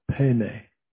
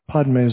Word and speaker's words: Padme: The Tibetans say, they pronounce it Pene, but whatever. Padme is the Sanskrit way it's written.